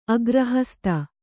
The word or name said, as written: ಅಗ್ರಹಸ್ತ